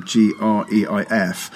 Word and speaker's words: Greif: I'm not sure how you pronounce his name. Greif, rather than IEF, who founded the brilliant magazine N+1.